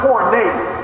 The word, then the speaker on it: porneia: And it uses the Greek word P-O-R-N-E-S. The law here is pronounced porneia.